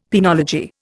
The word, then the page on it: penology: Indian